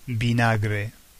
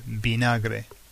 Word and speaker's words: vinagre: Vinegar is vinagre, vinagre. It's exactly the same word, just pronounced in a Spanish way, vinagre.